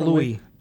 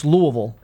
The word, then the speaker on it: Louisville: Did you want to pronounce that slightly It's not Louis. – Are you from one of these SEC Louisville. Cities now? – It's Louisville.